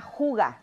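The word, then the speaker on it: hygge: Es el arte de ser feliz en lo sencillo y se llama juga. Se escribe H Y G G eh, H Y Ay, ya se me olvidó.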